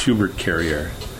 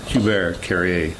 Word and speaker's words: Hubert Carrier: My actual real name was given name from my father, and it's Hubert Carrier. Hubert Carrier. – Oh, okay. – That's how you kind of pronounce it in French.